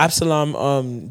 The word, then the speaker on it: Absalom: So now Absalom, I think I pronounced his name right. – Yeah. – Absalom, David's son, is now trying to kill him.